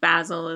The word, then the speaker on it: Basel: Basel. Saying it like the Brits, but it's not even spelled like the herb. – How's it spelled? – B-A-S-E-L. – I think Basil is probably as accurate as not.